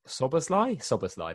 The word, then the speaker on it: Szoboszlai: Szoboszlai, that's how you say it.